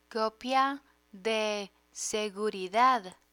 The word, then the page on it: copia de seguridad: Spanish